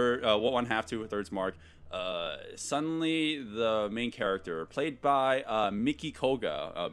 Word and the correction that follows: Mitsuki Koga: Mitsuki Koga. – Yeah, okay, making sure I pronounce that correctly.